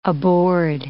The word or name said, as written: aboard